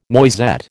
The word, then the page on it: noisette: American English